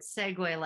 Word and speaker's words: segue: No, it's segue like the awesome little scooter thing.